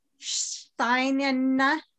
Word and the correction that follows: Shanina: How do you say it? – Shanina. – Shanina. Okay. Thank you for that.